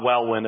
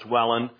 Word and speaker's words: Welwyn: Wellen is spelled W-E-L-W-Y-N, but it's pronounced not Wellwyn, it's Wellen.